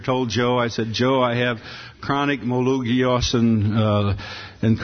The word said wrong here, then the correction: myelogenous: of course, he immediately corrected me. He said, "If you're going to have the disease, at least know how to pronounce it." And so it's a chronic myelogenous leukemia, and he's amazing.